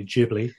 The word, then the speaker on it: Ghibli: It, it is it is apparently Ghibli, is how it's pronounced, uh, according to my.